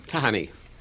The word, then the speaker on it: Tahani: Tahani. – Tahani. – There you go. – Tahani. – Sort of like tahini, except not. – Yeah.